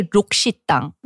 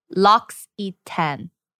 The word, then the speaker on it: L'Occitane: Okay, Koreans say Lukshitang. – Lukshitang. – And then I went on a trip with them in 2019. And I was like saying, L'Occitane. And then in my comment section, everyone went to town on me telling me that it's L'Occitane.